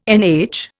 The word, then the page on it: NH: Indian